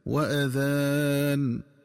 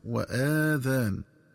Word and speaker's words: وأذان: ينبغي التنبه للنطق الصحيح لكلمة وأذان، فتنطق بهمزة مفتوحة دون مد هكذا وأذان بمعنى وإعلام، ومن الخطأ قراءتها بفتح الهمزة وإشباع فتحتها حتى يتولد منها ألف فتصير وآذان، والآذان جمع أذن.